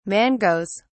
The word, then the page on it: mangoes: British English